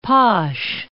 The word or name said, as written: posh